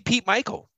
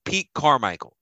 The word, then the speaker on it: Carmichael: Don't be Pete Michael. Be Pete Carmichael.